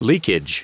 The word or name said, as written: leakage